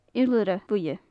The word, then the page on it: iolaire-bhuidhe: gaelic